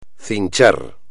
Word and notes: cinchar: espagnol

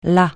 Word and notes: là: francais

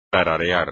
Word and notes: tararear: espagnol

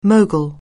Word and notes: mogul: anglais

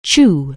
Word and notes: chew: English

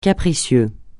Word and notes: capricieux: francais

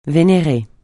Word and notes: vénérer: francais

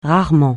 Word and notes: rarement: francais

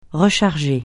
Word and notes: recharger: francais

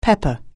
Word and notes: pepper: anglais

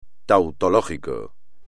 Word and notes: tautológico: espagnol

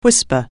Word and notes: whisper: anglais

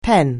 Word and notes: pen: anglais

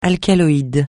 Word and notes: alcaloïde: francais

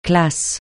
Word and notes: classe: francais

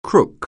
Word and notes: crook: anglais

